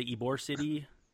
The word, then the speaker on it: Ybor City: They're trying to strike a deal in, is it, how do you say, Ybor City? – You got it.